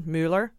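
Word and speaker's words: Müller: It's actually German, Mueller parrot. But that's how Mueller actually would pronounce his name if he were in Germany.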